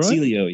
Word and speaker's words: Celio: Celio, yeah.